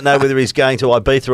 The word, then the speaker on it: Ibiza: And he reckons, well, Dusty reckons I should go to Ibiza. Is that how you say it? – Yeah, Ibiza.